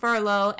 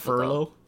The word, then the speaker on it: Frollo: So this, in turn, pisses off Frollo and calls for his death. I keep saying this guy's name wrong. Frollo. – I think it's very difficult. Furlough? – I keep saying Furlough.